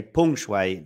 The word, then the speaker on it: Peng Shuai: In fact, I should say Peng Shuai, the correct pronunciation.